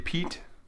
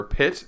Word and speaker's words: Piet: Zwarte Piet, or Pit. It's like spelled P-I-E-T.